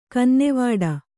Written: ಕನ್ನವಿಡು

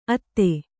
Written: ಅತ್ತೆ

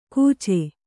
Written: ಕೂಚೆ

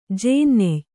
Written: ಜೇನ್ನೆಯ್

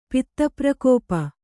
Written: ಪಿತ್ತಪ್ರಕೋಪ

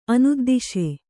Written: ಅನುದ್ದಿಶೆ

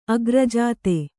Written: ಅಗ್ರಜಾತ

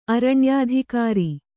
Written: ಅರಣ್ಯಾಧಿಕಾರಿ

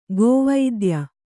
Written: ಕುವಿದ್ಯೆ

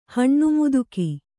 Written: ಹಣ್ಣುಮುದುಕಿ

